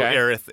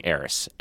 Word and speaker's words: Aerith: But I know Aerith is, like, what the proper way to say people say Aerith.